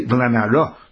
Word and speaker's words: V'lanara: Even though it's spelled without a hey, we pronounce it with a hey. V'lanara loisasa dover.